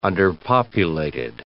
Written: underpopulated

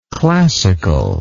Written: classicalness

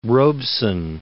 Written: Robeson

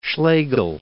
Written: Schlegel

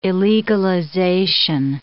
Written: illegalization

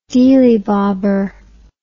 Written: deelybobber

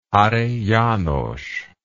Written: hary janos

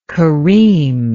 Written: kareem

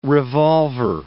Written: revolver

